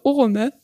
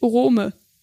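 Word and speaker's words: Oromë: Zum Beispiel, in Orome ist die vorletzte Silbe ro leicht, denn da ist nur ein kurzes O. Darum müsst ihr die Silbe davor betonen, also das O. Es ist also Orome und nicht Orome, was man auch öfter mal hört.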